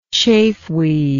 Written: chafeweed

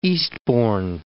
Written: Eastbourne

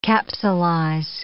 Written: capsulize

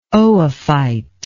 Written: oophyte

